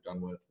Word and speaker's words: Dunworth: I'm on Twitter, but it's too awkward to say my name, Michael Dunworth, one without the H.